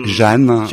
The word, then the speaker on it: Jeanne: You have Jeanne, which is the female name, but you pronounce it here like Jane.